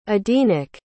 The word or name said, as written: edenic